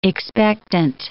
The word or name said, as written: expectant